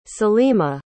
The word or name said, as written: salima